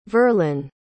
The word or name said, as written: verlyn